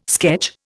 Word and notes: sketch: Indian

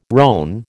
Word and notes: rone: American English

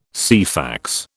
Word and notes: Ceefax: British English

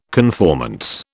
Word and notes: conformance: British English

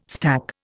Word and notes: stack: Indian

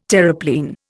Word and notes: Terreplein: Indian

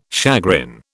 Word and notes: chagrin: British English

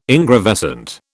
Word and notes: ingravescent: British English

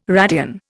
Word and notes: radian: Indian

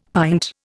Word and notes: pint: Indian

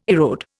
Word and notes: erode: Indian

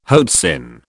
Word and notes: hoatzin: British English

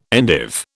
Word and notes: endive: British English

